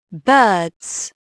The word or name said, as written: birds